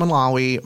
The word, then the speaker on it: Malawi: And then she was adopting all the kids from Malawi.